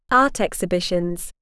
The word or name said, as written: art exhibitions